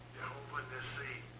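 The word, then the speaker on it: Yehovah Nissi (יהוה נסי): Yehovah Nissi.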